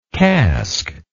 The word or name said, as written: casqued